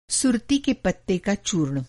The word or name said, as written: सुरती